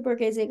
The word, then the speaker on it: Borghese: The Borghese Gardens, Borghese Gardens was generative to you.